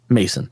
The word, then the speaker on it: Maeson: So there's an R&B artist I like called Matt Mason, and it's called M-A-T-T, and his last name is spelled M-A-E-S-O-N, Mason.